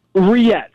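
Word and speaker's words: rillettes: Pork butter how's it pronounced? Roulette? – Riette. Yeah. Riette. So, like silent L's, but yeah, it's okay. Two L's.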